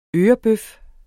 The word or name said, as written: ørebøf